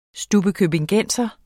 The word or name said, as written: stubbekøbingenser